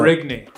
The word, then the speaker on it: Marigny: And so, Marigny.